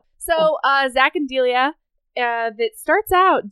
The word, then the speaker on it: Delia: It's Delia. That's how it's spelled. – Wait, D-E-L-I-A. Delia. – Delia. – Delia or Delia. I think it's Delia.